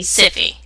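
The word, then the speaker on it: Syfy: Sci-fi has got two things. – Okay, first of all, you said it wrong, so you get this one. Siffy.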